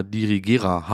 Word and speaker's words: Dirigera: Dirigera Hub.